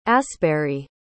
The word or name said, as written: asberry